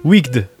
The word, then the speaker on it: Wicked: C'est une sorte de magicien d'Oz, hein, revue corrigée et modernisée qui donne Wicked.